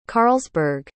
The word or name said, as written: carlsberg